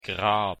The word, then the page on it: grab: German